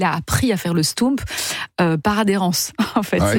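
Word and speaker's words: stoemp: Ça s'appelle un stoump. Et ça s'écrit S-T-O-E, parce que O-E en belge, ça fait où? – M-P. – Ah, un stoump.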